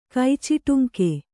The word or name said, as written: ಕೈಚಿಟುಂಕೆ